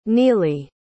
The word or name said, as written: nealy